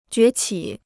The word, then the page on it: 崛起: jué qǐ